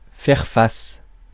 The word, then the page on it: faire face: /fɛʁ fas/